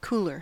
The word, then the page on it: cooler: American English